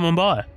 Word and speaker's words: Mumbai: Let's to your film, Hotel Mumbai. Am I saying that right? – Yes, Hotel Mumbai.